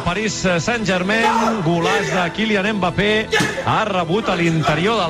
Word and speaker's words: Mbappé: ¡Mbappé! – Mbappé le ha pintado la cara al Barça. – Vale, pero no se pronuncia Mbappé, la M es muda.